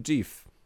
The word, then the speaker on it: GIF: Serwis Giphy w partnerstwie z producentem masła orzechowego GIF rozwiązał odwieczny problem wymowy słowa GIF za pomocą pary słoików z masłem orzechowym. Promocyjna para słoików z masłem orzechowym nazwana odpowiednio GIF i GIF. Także Mateusz, zostało postanowione. Wymawia się GIF.